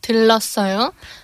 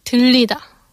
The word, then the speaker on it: 들르다: However, Koreans often pronounce this word as 들리다. – Okay. – Which is the same as to be heard or can hear. So if you use 들리다, the future tense, I'll come by, becomes 들릴게요. And also, please come by becomes 들리세요. Mm. So if you just mispronounce a single vowel, 으, as E, all the conjugated forms also become different.